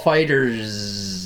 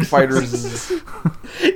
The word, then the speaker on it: FighterZ: That is how you pronounce it. It is pronounced FighterZ. – There's no space there. It's not FighterZ. It's FighterZ. – Yeah. – Yeah, well, that sounds ridiculous, so it's FighterZ. – No, it's officially FighterZ.